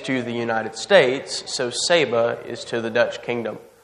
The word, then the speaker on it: Saba: Not Saba, which is how I pronounced it for like 4 months leading up to this. My bad on that one. It is Saba.